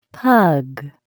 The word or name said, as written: pug